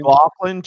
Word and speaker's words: McLaughlin: McGloin looked really good the other day. You know? McGloin, I think is that how you pronounce his name? – McLaughlin, Jaleel McLaughlin.